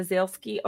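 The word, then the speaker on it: Zaleski: Yeah, Zalesky, like Zalesky. The diamond people.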